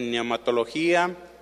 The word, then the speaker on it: neumatología: El tema número 6 de neumatología, recuerde cómo se escribe, PNE. U-M-A-T-O-L-O-G-I, acentuada A. O neumatología. Neumatología, nada más le pone al principio la P.